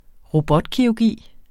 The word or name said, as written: robotkirurgi